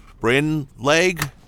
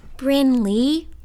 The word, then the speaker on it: Brynleigh: Bryn Leg, is it? – Bryn Lee, Pasternak, sir. The G is silent.